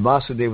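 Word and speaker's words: Vāsudeva: But Vasudev with a short A is the father of Krishna, And Vasudeva with a long A is Krishna.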